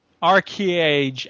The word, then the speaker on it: ArcheAge: A-R-C-H-E-A-G-E. Arcage. And the second A is capitalized. – Yeah, Arcage.